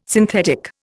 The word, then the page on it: synthetic: Indian